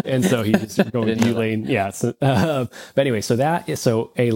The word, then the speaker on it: Youlean: But it's the Ulean loudness meter. – It's a funny name, but it's spelled Y-O-U. L-E-A-N.